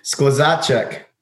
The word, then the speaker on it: Skluzacek: Skluzacek.